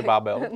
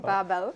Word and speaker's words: Babel: Myslím, že Babel. Babel.